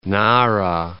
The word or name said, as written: Nara